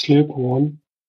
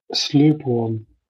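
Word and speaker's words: Sløborn: Slöborn. Was? Ja, Slöborn. Hat so geschrieben wie, so geschrieben wie, so würde man es dänisch schreiben mit dem durchgestrichenen U, was dann als Öl, glaube ich, ausgesprochen wird.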